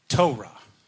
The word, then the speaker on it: Torah: That word law is pronounced Torah in Hebrew.